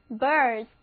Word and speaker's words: birth: 好啦，最后要看的这个词还是从 bear 这个表示生育的词根来的， birth B-I-R-T-H birth，注意字母组合 th 的发音，要轻轻地咬住舌尖再送气，发音标准了别人才能够听懂你说的话。